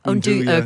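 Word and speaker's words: nduja: Is it Unduja? I never know how to pronounce it. – Unduja. – Okay, sorry.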